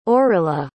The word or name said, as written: aurilla